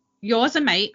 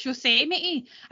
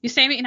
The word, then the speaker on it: Yosemite: Yosemite. Oh no, not Yosemite. mate. always pronounce that wrong. It's Yosemite. I need to think of Yosemite Sam. Yosemite Sam. Yosemite National Park security camera original footage.